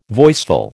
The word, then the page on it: voiceful: American English